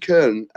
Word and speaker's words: Köln: And as um, Cologne or FC Köln, as um, as the Germans call it, what was my pronunciation like?